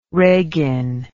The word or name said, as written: regin